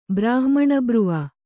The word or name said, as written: ಬ್ರಾಹ್ಮಣಬ್ರುವ